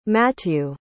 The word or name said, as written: matthieu